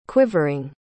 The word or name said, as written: quivering